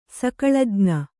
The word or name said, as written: ಸಕಳಜ್ಞೆ